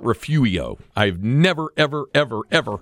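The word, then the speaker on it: Refugio: Somebody gave me a pronouncer and Refurio went Refuio. I've never, ever, ever, ever, ever, ever heard that. – It's a silent R.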